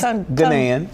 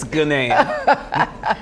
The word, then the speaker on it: Ghanaian: Just Ghanaian. Just Ghanaian. Ghanaian. – Ghanaian. But it's spelled Ghanaian. – It's Ghanaian. Yes.